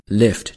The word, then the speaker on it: Lyft: 只不过那个单词的拼法呢是 L-I-F-T, Lyft。 For example, to give somebody a lift, 啊，捎某人一段就可以用这个词。 I would like to have a free ride. Can you give me a ride? Can you give me a lift? 都会用到这个词。 – 所以呢，这家公司在自己起名字的时候呢，就把这个 I 改成了 Y, 但是它读的时候呢，仍然读成 lift 这个词。